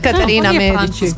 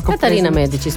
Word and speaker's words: Katarina Medici: Da, nametnula ga je opet u renesansi francuska kraljica, Catherine de Medici. – Katarina Medici. – Katarina Medici.